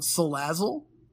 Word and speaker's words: Salazzle: So what makes this card very, very, very unique is also the addition of Salazzle.